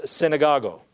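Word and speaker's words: synagogo (συναγωγή): Now, the word synagogue comes from the Greek word pronounced synagogo, and it means a gathering place.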